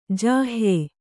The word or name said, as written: ಜಾಹ್ಯೆ